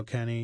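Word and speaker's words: Kenney: So all my music is available under my name, under all the streaming platforms, under Joe Kenny.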